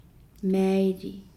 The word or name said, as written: maaili